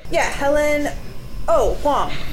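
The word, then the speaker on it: Hoang: Helen Hong.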